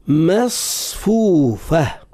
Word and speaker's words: مصفوفة: ونمارق مصفوفة، حرف الميم والصاد والفاء بعد الصاد، ما مص فو فو فا، لابد أن نفخم صوت الصاد تفخيما ناشئا عن صفتي الاستعلاء والأطباق، ولا وردوا بالنا باش ما يتأثرش الميم قبل الصاد بالتفخيم، فيصبح النطق بالميم ما مصفوفة، والفاء يولي فو فو، وهو فا فو مصفوفة.